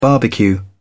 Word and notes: barbecue: British English

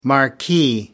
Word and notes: marquee: American English

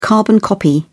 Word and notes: carbon-copy: British English

